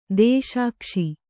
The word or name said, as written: ದೇಶಾಕ್ಷಿ